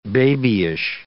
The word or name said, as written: babyish